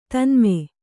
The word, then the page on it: ತನ್ಮೆ: tanme